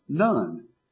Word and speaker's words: nun: The letter is pronounced Nun, which reminded me of Joshua, the son of Nun.